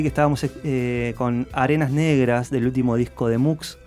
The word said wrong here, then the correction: Xum: Xum, si no lo pronuncio mal, X-U-M.